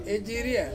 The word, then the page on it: iria: Ansus